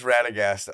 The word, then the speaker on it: Radagast: It is Radagast,